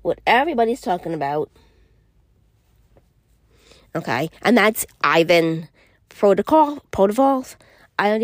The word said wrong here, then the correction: Provorov: Ivan Provorov.